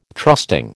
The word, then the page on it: trusting: American English